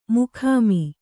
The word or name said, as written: ಮೊಖಾಮು